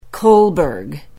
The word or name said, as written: kolberg